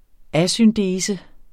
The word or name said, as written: asyndese